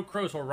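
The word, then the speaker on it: Krozel: Thursday, October 29th by Joe Crozel, rhymes with puzzle.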